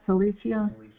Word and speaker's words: Cilicia: So they being sent forth by the Holy Spirit departed into Cilicia. How do you say that? Cilicia? – Cilicia.